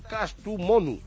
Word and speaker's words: Kastamonu: no sé si lo digo bien, del Kastamonu. – Sí, señor, muy bien.